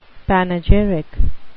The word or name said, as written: panegyric